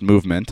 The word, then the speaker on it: MVMT: It's pronounced movement.